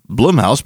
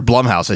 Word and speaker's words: Blumhouse: Rather than building an anthology house based after other movies, like Blumhouse being the, or Blumhouse, I should say. I was so conscious and to say that right, because I knew Shelby was going to get pissed and probably tweet about it if I said it wrong, and there I said it wrong.